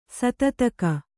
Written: ಸತ್ತುತೆಗೆ

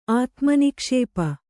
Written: ಆತ್ಮನಿಕ್ಷೇಪ

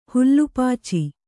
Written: ಹುಲ್ಲುಪಾಚಿ

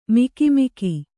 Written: ಮುಖಾಮುಖಿ

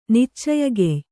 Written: ನಿಚ್ಚಯಗೆಯ್

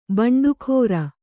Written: ಬಂಡಿಕಾರ

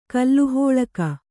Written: ಕಲಹಾಳಿಗ

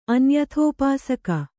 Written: ಅನ್ಯಥೋಪಾಸಕ

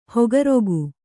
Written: ಹಕ್ಕಿರೋಗ

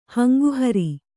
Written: ಹಂಗುಹರಿ

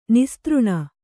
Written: ನಿಸ್ತøಣ

